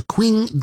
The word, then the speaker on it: Qing: under what they call the Qing Dynasty or the Qing Dynasty. It's spelled Q-I-N-G.